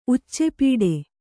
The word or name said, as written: ಉಚ್ಚೆಪೀಡೆ